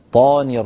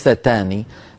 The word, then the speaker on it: الشيطان: الشيطاني، الطاء مفخمة، الله يبارك فيك يا أختي، لا تقولي الشيطاني، الشيطاني هذه صارت تاء وهي طاء. أعوذ بالله من الشيطاني طاني الرجيم وليس تاني.